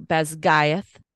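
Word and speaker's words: Basgiath: Yes, it's Gaelic, not Gaelic. There's two different languages that are spelled the same but pronounced differently, and the pronunciations that she uses apparently are Gaelic. So Beskayeth is how I understand that to be.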